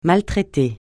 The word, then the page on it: maltraiter: francais